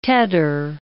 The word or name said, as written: tedder